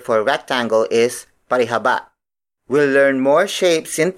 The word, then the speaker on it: parihaba: The last shape on this batch is parihaba. Parihaba. Parihaba. Parihaba is spelled as P-A-R-I-H-A-B-A.